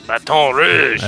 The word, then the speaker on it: Baton Rouge: Baton Rouge. – That's right. – Kat, are we saying that right? – Yes, Red Stick.